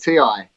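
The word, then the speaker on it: T.I.: It's just pronounced T-I.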